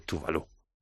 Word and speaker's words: Tove Lo: Tuvalu, que ya te digo, se escribe Tobe con V, Lo, pero se dice Tuvalu.